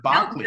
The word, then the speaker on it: Berkeley: I always thought it was Berkeley, but they call it Barclay.